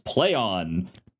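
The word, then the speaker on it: Plaion: it is pronounced play on.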